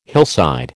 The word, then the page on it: hillside: American English